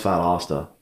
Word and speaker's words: Valdosta: So, like, nobody knows how to even pronounce Valdosta, by the way.